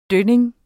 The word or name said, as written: dønning